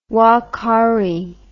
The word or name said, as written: ouakari